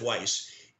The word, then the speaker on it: Weiss: As you say, Weiss, and it's pronounced Weiss, is he's had this.